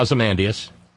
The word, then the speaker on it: Ozymandias: Yeah, Ozymandias.